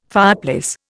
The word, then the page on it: fireplace: Indian